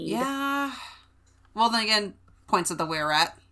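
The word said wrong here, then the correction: Wearat: The wee rat. – I was pronouncing it were rat, like a were rat. – But it's supposed to be a weasel rat. Oh, yeah, wee rat. A wee rat.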